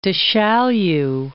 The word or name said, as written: Du Chaillu